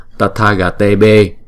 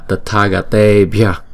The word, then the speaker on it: tathagatebhya: Chữ tathagatebhya được phát âm là tathagatebe thay vì là tathagatebhya.